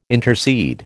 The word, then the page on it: intercede: American English